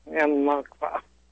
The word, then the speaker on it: Al-Nakba: My guest will be co-founder of the Eugene, Oregon local Al Nakaba. I think I spelled that right. I don't know or said it right. Awareness Project. A group of Al-Nakba. – Okay, thank you.